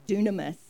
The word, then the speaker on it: dunamis: And this word is pronounced in the Greek dunamis, and it's a very important word.